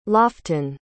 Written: loften

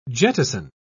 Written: jettison

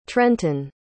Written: trenton

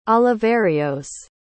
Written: Oliverios

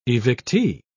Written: evictee

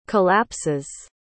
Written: collapses

